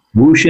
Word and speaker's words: WHOOSHH: WHOOSHH Innovations.